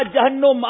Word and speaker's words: جہنم: آپ نے کہا جہنم. غلطی نہیں کرتے مگر ان سے بھی غلطی ہو گئی ہے. لفظ ہے جہنم، مقابلہ برابر ہو گیا اور تینوں غلطیاں کی ہیں بلوچستان والوں نے.